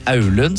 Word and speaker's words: Alunsjøen: Det riktig uttale der? Alun, Aulun, sa jeg. Ja, Alun.